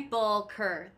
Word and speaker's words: Sepulchre: Sepulchre. That is how you say it.